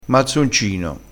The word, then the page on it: mazzoncino: dialettale